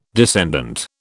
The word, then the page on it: descendant: British English